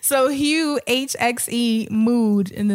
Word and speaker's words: Hxe: So this artist named Hugh, and of course, here y'all go throwing X's and shit. It's spelled H-X-E.